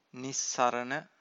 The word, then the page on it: nissarana: Pali